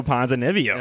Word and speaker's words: Ponzinibbio: Oh, Santiago Ponzinibbio.